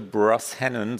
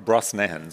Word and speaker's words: Brosnahan: Ähm, erfunden von Amy Sherman Palladino und Rachel Brosnahan, so spricht man sie, nee, Bro Brosnahan, so spricht man sie aus, ist Miriam Maisel und The Marvelous Mrs. Maisel, Amazon Prime.